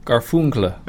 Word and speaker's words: Garfunkel: A sound reminiscent of, I don't know how to pronounce this band, Simone and Garfunkel? – Garfunkel.